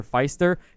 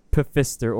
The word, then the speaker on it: Pfister: But I have people say Pfister or Pfister. – Pfister. – I'm just like, what the fuck? Clearly the P is silent, dude.